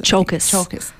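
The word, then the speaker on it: Tsiolkas: It's a short film about motherhood, sexuality and grief based on the short story by Christos Tsolkis. – Chalkis. – I need to learn my pronunciation.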